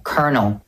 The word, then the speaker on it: colonel: But the thing is, it's not colonel, it's colonel, colonel. A fun way to remember its pronunciation is thinking of this. This is a colonel, colonel. They have the same pronunciation.